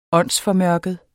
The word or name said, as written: åndsformørket